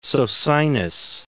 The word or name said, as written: Socinus